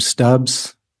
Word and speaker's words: Stubbs: Last name, Stubbs. Spelling of last is S-T-U-B-B-S.